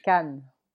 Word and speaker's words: Cannes: Cannes.